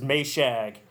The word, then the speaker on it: Maciag: It's actually, the way that I pronounce it is Maciag.